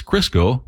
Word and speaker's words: Crisco: It's called Crisco, like the stuff your grandma used to fry chicken in. That's what it is. Crisco rhymes with disco.